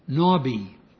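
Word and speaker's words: Nabi (נביא): It is Strong's number 5030 and is pronounced Nabi.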